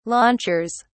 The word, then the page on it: launchers: British English